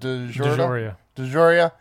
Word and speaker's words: DeJoria: Is Alexis, how do you pronounce it? – DeJoria.